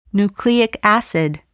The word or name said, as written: nucleic acid